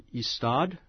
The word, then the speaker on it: Ystad: Kurt Wallander is a detective in the city of Ystad.